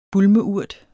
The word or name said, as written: bulmeurt